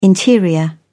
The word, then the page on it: interior: British English